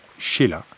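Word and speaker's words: Chayla: Le chef de la persécution était François de Langlade du Chéla, prononcé Chéla, archiprêtre des Cévennes et inspecteur des missions dans la même région.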